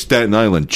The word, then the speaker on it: Staten Island: But you did say it right. It was Staten Island Chuck.